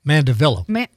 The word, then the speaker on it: Mandevilla: Mandevilla. Maybe a mandevilla, you think? – Oh, maybe. Montavia. No. I thought I was doing really good and pronouncing something beautifully.